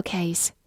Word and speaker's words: case: case，c a s e，case，字母 a 发它本身的音 a，末尾的 e 不发音，case。它是一个名词，表示相和或者实例或者情况。比如说，pencil case 就是铅笔盒，case 在这里相当于 box，pencil case。